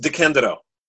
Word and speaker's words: DeCandido: No, it's pronounced DeCandido.